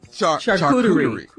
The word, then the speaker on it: charcuterie: Yeah, how you say it? – Charcuterie. – Okay, charcuterie.